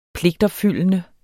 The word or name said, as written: pligtopfyldende